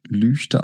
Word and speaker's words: Lügde: Der wird Lüchte geschrieben und Lüchte ausgesprochen. Es ist sehr wichtig, dass wenn man da ist, auch wirklich Lüchte sagt.